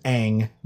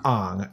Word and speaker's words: Aang: We're laughing because if you're not a fan of the Avatar series, his name is Aang, not Ong.